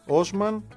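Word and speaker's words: Osman: και είναι Τζέντι, όχι Τσέντι, Τζέντι Όσμαν.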